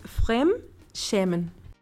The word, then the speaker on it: Fremdschämen: Entonces, ¿cómo se pronuncia otra vez? – Ese es Frem shemen. – Frem Schemen. – Ajá, sí, esa como A con dos puntitos arriba que conocemos nosotros se llama A umlaut y suena a E.